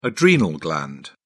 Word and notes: adrenal gland: British English

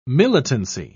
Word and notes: militancy: British English

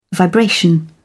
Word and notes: vibration: British English

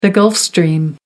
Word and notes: Gulf Stream: American English

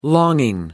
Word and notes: longing: American English

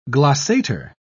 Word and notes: glossator: British English

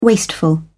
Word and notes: wasteful: British English

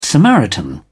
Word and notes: samaritan: British English

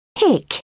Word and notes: hick: British English

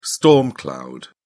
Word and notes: storm cloud: British English

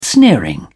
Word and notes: sneering: British English

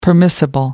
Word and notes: permissible: American English

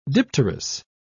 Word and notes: dipterous: British English

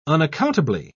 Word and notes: unaccountably: British English

